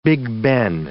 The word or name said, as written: Big Ben